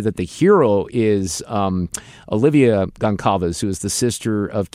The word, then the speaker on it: Goncalves: It's a little hard to pronounce her name. It's like Gonzalez, but it's Goncalves.